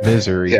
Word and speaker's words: Missouri: For those of us who know better, Misery. – Yeah. – Misery. – But when you're on Hank's side of Missouri, that's how you pronounce it.